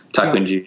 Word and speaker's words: Takunji: Takunji yeah. is how you pronounce it in Japanese, I was told.